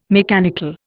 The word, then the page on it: mechanical: Indian